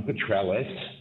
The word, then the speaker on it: Petrelis: My name is Michael Petrelis. My surname is spelled P-E-T-R-E-L-I-S.